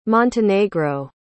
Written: montenegro

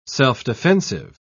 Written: self-defensive

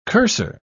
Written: cursor

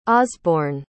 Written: osborne

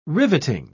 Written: riveting

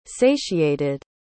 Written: satiated

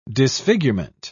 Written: disfigurement

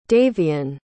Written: davion

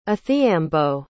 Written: athiambo

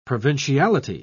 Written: provinciality

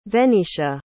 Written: venitia

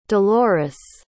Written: delores

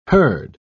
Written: herd

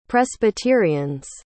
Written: presbyterians

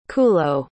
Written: cullo

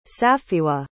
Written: safiwah

